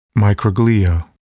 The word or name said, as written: microglia